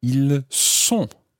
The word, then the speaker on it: ils sont: And then the il and elle plural form, ils sont. Ils sont. Now, listen carefully to that one. Ils sont. You're saying S-O-N-T. Ils sont.